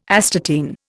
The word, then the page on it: astatine: Indian